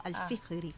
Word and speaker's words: Al-Fihri: ¿Lo dije bien? – Sí, está correcto, Al-Fihri.